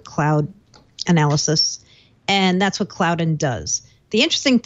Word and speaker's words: Cloudyn: This company, I think they pronounce their name Cloudyn, C-L-O-U-D-Y-N, Cloudyn.